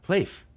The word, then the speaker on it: Leif: It looks like leaf, but it's pronounced okay. Leif.